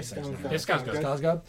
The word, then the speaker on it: Skarsgård: Skarsgård's good too. I think I'm saying his name wrong. – Yeah, Skarsgård.